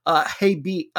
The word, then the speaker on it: Hebei: Interestingly, he came here last year from now a team that's in last place in this league, which is Hebei, I think it's pronounced.